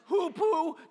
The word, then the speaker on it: hoopoe: the hoopoe, and the bat. All right, you got it? That's pronounced hoopoe, just like that, right.